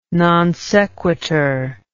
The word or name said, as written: non sequitur